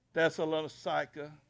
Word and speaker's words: Thessalonica: In that time, in that period, in Thessalus, Joe Biggs, how do you say that? Joe don't even know. Thessalonica, whatever she said.